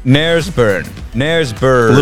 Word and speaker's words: Knaresborough: Knaresborough. – Little known fact, the original Clam Harbor. – The original Clam Harbor, and it starts with a K. And I don't think you pronounce the K.